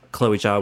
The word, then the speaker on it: Zhao: I've been saying Chloe Zhao. – Oh, yeah. – It's Chloe Zha, yeah. – Apparently, is how it's pronounced.